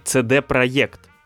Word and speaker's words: CD Projekt: Насколько я знаю, правильно название этой студии произносится CD Projekt.